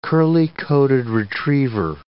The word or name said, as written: curly-coated retriever